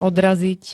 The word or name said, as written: odraziť